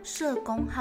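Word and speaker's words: 叶公: 你们知道“ 社公好龙” 这四个字怎么写吗？第一个字就是我们的姓氏的“ 叶”，也就是叶子的“ 叶”。当它念作“ 社” 的时候呢，表示是一个地名，是春秋时代楚国的一个封地。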